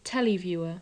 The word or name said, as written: televiewer